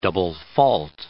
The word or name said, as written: double-fault